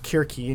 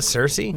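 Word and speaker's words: Circe: Her name's pronounced Kirki, in case anybody wanted to know. Because not Cersei? – Nope, it's Greek. There's Greek, no Kirky. Soft letters in Greek. Kirky. It's Kirki.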